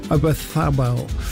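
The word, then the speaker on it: Oyarzabal: Lamine, Yamal, Olmo, Bayena and Ozabal, what is up front? I didn't say that right, did I? I know. Obathabel.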